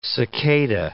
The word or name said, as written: cicada